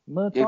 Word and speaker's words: Murtagh: His surname, M-U-R-T-A-G-H, Murtar? Murtar? – Exactly, yep, spot on.